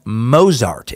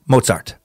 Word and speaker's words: Mozart: In the Einstein Time Suck, you pronounced Mozart incorrectly. I swear I'm not being picky and asking for the perfect German pronunciation. The Z is pronounced TS.